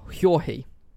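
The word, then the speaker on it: György: Her father was a baron, and it says George, but apparently it's pronounced like Jorge or Hjorhi, I think.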